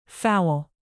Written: foul

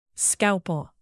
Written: scalpel

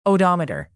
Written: odometer

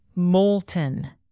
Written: molten

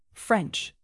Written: french

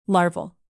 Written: larval

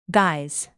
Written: guise

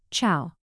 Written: chow

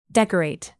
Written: decorate

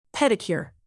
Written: pedicure

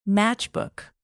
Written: matchbook

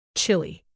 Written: chili

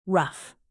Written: rough

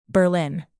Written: berlin

